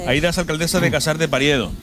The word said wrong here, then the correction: Periedo: ¿Lo he dicho bien? – Periedo.